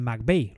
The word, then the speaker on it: McVay: entrenador de quarterbacks con los Rams de McVay. Lo digo bien, ¿verdad? – Lo dices bien, lo dices bien.